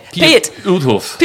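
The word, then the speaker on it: Piet Oudolf: How do you pronounce Pieto Duf? How do you pronounce his name? – Piet Uthoff. – Piet Uthoff does.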